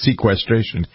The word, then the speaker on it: sequestration: We spent the whole of the first hour talking about sequestration. I got to pronounce it right. There's an R in it.